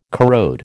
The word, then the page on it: corrode: American English